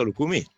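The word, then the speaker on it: Lucumí: Camillo mi dice che si dice Lukumi con l'accento sulle I, te lo dico io Ive, Camillo dice Lukumi.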